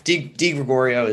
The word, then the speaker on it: DeGregorio: DeGregorio is good.